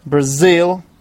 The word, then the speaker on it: Brazil: Brazil, again, the stress is on the second syllable.